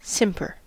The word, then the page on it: simper: American English